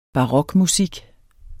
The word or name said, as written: barokmusik